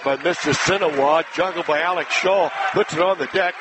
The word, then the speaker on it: Scholl: It goes against the Redskins, 2 free throws coming for Alex Shaw. Pronounced as if it's S-H-A-W, but spelled S-C-H-O-L-L.